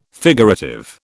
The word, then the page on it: figurative: British English